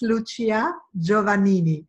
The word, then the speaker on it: Lucia Giovannini: It's Lucia Giovannini. That's the Italian pronunciation.